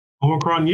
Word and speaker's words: Omicron: Is it pronounced Omicron? – Omicron, yeah. Yeah.